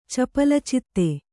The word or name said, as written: ಚಪಲಚಿತ್ತೆ